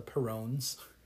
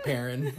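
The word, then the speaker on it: Perron: and I kept thinking in my head that it was the Perrones. – Perrones. – Yeah. Like, and then I finally heard him say it like Perrin. I'm like, oh, okay. That makes more sense.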